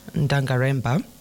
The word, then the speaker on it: Ngaremba: I'm assuming Ngaremba. – Okay. – Correct. The G is like the G in lasagna.